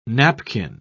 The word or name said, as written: napkin